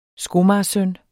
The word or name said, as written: skomager